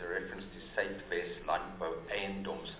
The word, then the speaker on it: Eiendoms: It's spelled E-I-E-N. – Eiendoms. – It's at E-I-E-N-D-O-M. Eiendoms. – Can we get in one more sort? – As it is there, it says it's very Aina. So the word is spelled incorrectly. It's E I E. – Or N. Eiendoms. – Eiendoms, not Aina Doms.